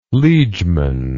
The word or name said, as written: liegeman